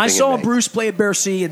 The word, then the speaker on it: Bercy: Is it Bercy? Is that how you pronounce it? Mm-hmm. When July 4th in 2013-14, Bruce played in Paris. Where do you remember? – He played Bercy.